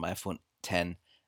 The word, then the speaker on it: iPhone X: Also es heißt tatsächlich iPhone X, beziehungsweise ähm, es schreibt sich iPhone X, es ist die römische 10 und die Aussprache ist eigentlich, da musste ich sofort an Mac OS 10 denken. Das schreibt sich ja auch Mac OS X oder schrieb sich Mac OS X, das X haben sie ja weggestrichen mittlerweile, wurde aber immer mh, hm, OS 10 gesprochen, also halt äh, Englisch. Und äh, diese Aussprache hat man sich dann beim iPhone 10 auch äh, zu eigen gemacht.